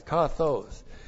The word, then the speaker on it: kathos: The adverb kathos, K-A-T-H, long O-S, kathos.